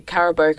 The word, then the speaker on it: Carabao: Well, obviously, the EPL doesn't happen midweek, but there was the Carabao Cup. Is that how you pronounce it? – Yes, yeah.